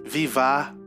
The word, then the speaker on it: vivat: E a gente pronuncia esta palavra francesa vivat, vivat, vivat, que pronuncia-se vivá, vivá, vivá.